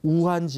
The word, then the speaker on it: 우한: 무한이라는 게 지금 우한이잖아요. 우리 중국 발음을 하면 우한이잖아요. 우한 지역에 있는 무한에 있는 이제 이 황학루예요.